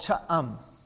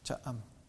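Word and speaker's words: Cha-am: The city is called Cha'am. Cha'am. Two syllables. Cha'am.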